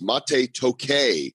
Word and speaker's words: Mate Tokay: His name is Mate Tokay, and it's spelled like mate, but it's M-A-T-E, and his last name is Tokay, T-O-K-A-Y, and that's Tokay with me.